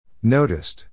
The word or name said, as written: noticed